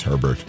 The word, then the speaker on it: Herbert: Justin, is it pronounced Hebert, the quarterback from Oregon? Or is he going with Herbert? – I think it's Herbert.